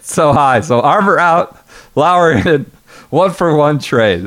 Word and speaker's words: Lower: Spelled lower, but pronounced Lauer.